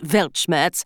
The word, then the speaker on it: Weltschmerz: As the polyglots among you are doubtless already aware, the German Weltschmerz is pronounced Weltschmerz, and it means...